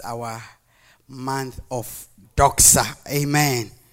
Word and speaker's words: doxa: But the thing says D-O-X-A. How do you want to pronounce it? Doza. Yeah. Doxa. Yeah.